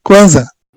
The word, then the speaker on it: Kwanzaa: Isso mesmo, Kwanzaa.